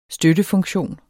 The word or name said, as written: støttefunktion